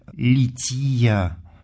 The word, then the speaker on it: лития: «Лития» или церковнославянское ударение «лития» – это часть богослужения,